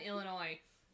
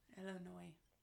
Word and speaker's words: Illinois: Illinois. – Huh? – It's French. – No. – Yes, Illinois is a French word. That's why you don't pronounce the S at the end.